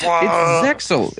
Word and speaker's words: Zexal: It's Zexal. It's Yu-Gi-Oh! Zexal.